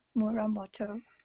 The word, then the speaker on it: Muramoto: Lena Muramoto. – Spell that, please. – Lena is spelled L-E-N-A. Muramoto, okay. – M-U-R-A-M-O-T-O.